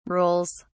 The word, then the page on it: rules: British English